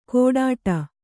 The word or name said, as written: ಗೊಡ್ಡ